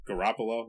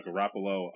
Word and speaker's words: Garoppolo: Uh, Garoppolo, that's how you say it. – Yep. Yeah, uh, Garoppolo.